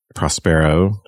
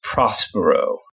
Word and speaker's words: Prospero: So the play is about Prospero, who was the one-time Duke of Milan, and was... – I think it's usually pronounced Prospero.